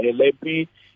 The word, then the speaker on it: Helepi: The name is Sello Helepi. Helepi is spelled H-E-L-E-P-I, and they can also get hold of me on my number.